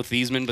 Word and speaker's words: Theismann: It's kind of like when Joe Theismann, it was actually Joe Theismann, but then he changed the pronunciation to rhyme with Heisman for his Heisman campaign.